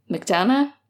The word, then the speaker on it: McDonagh: The Pillow Man by Martin McDonagh. McDonagh? I don't know how to say his name. I'm sorry. He's very famous. I should know, but I think you said it right.